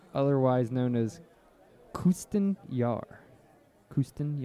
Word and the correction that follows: Kapustin Yar: Kapustin Yar. – Kapustin Yar. – Kapustin Yar. – Yeah, there's a P in there.